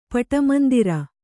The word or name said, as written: ಪಟಮಂದಿರ